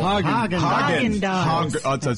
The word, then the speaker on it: Häagen-Dazs: Häagen-Dazs.